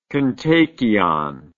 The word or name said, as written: kontakion